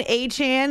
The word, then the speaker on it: Achane: Devon Achane, that's how he wants it pronounced, was the offensive player of the week for his 4 touchdowns.